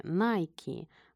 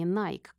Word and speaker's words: Nike: Кстати, она так и произносится по-английски – Nike, а не Nike, как мы привыкли говорить по-русски.